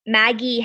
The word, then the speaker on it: Maggi: Yeah, so it's Maggi. And then her last name is Hambling.